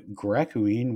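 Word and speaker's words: Gracouine: There's also a world called Gracooine, which is spelled slightly differently, ending in O-U-I-N-E, but sounds the same when pronounced.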